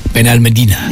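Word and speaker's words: Benalmádena: Over there they say Ben. – Ben Almedina.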